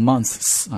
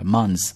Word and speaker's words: months: 如果加了 s，你不要读成 months 啊，直接读 z sound 就好了。 months 啊，for 18 months。